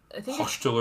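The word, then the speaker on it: Xochitl: Gomez, it's like Hochtel or something like that. It's X-O-C-H-I-T-L, I think.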